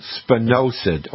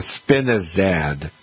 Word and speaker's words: spinosad: And the active ingredient in Captain Jack is something called spinosad or spinosad. And it's spelled S-P-I-N-O-S-A-D.